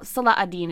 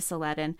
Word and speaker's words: Salah ad-Din: The binary begins with the Arab conquests in Europe and is continued in the medieval interactions between Saladin and, well, Salah ad-Din is really the more better way to say his name, but I think it's been anglicized to Saladin, between Saladin and Norman, the Norman Richard III.